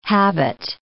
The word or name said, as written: habit